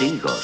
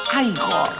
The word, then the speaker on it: Igor: Usted debe de ser Igor. No, se pronuncia Igor.